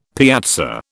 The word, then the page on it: piazza: British English